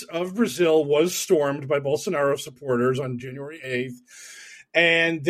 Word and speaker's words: Bolsonaro: Bolsonaro? I don't know how to pronounce Bolsonaro. – That. – Bolsonaro. You had it right.